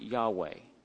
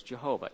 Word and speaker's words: Yahweh: That's why a lot of people pronounce it Yahweh. And without going into the reasons why, it's also the name Jehovah. Some people have pronounced it as Jehovah. Either way is acceptable if you know the background behind it.